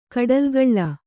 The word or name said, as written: ಕೂಡಲುಗೊಳ್